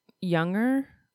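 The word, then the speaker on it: Junger: Directed by Gil Younger. I'm going to say the J is like a Y.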